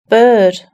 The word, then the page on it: bird: British English